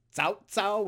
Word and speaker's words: Cao Cao: Usually the pronunciation we're most used to, it's Cao Cao, which is sort of like the T-S sound you find in East Asia a lot. Like with tsunami.